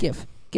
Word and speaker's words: GIF: GIF. – It's GIF. It's like the creator said it's pronounced like the peanut butter.